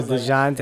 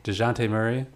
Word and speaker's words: Dejounte: I think the Atlanta trade, they got Dejan. – Oh, DeJounte. – Like, I was like, I need a sandwich. Give me some mustard. You say his name. – DeJounte, man. DeJounte. – DeJounte Murray. – That's it.